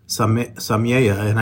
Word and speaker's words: Samayoa: Interesting, your name is Samayoa, and when you walked into the office, I said, sounds like Samayoa.